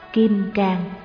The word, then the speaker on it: Kim Cang: Kim Cang, chữ can người Nam đọc là can, người Bắc đọc là cương, có hai nghĩa.